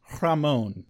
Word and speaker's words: Rahmon: R-A-H-M-O-N. – Chicken or beef? Oh, Ramon.